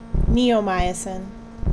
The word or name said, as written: Neomycin